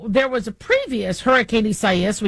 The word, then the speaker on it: Isaias: So, the media is reminding us that we are pronouncing it Isaias, so because that is the Latino term for Isaiah. And so even though Isaias is where how many of us Americans would call it, the Spanish pronunciation is what they are using.